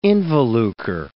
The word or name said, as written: involucre